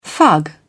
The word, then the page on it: fug: British English